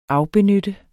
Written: afbenytte

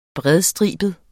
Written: bredstribet

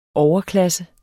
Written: overklasse

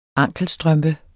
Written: ankelstrømpe

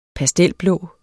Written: pastelblå

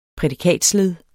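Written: prædikatsled

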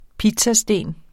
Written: pizzasten

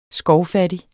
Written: skovfattig

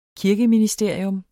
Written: kirkeministerier

